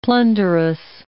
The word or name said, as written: plunderous